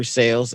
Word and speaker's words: Sayles: It's called Sales Tales, S-A-Y-L-E-S, Tales, T-A-L-E-S. My name's Andrew Sales, S-A-Y-L-E-S. So that's why it's spelled like that.